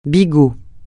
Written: bigot

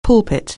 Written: pulpit

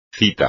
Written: cita

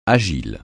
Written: agile

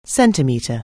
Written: centimetre

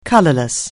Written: colourless

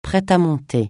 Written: prêt-à-monter

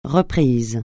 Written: reprise

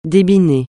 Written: débiner

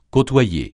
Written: côtoyer

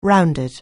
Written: rounded

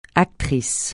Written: actrice

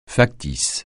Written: factice

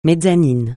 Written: mezzanine

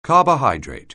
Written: carbohydrate

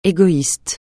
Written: égoïste

